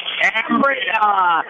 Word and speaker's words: Cambria: Therefore, we submit to you that the proper pronunciation of our hometown is Cambria, a quintessentially quaint community, no matter how you say it.